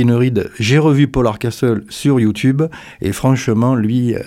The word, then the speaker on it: Hardcastle: Alors, il paraît qu'il ne faut pas prononcer le T en anglais, donc on va dire Paul Hardcastle, qui a eu son heure de gloire dans les années 80.